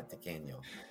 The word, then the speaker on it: tequeño: I would want blood if I saw a booger in my tequeño. Did I say it right? – Yeah, that was perfect, actually.